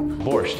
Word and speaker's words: borscht: Borscht, did I say it right? – Yeah.